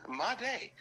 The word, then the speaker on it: Madej: Yeah, Madej.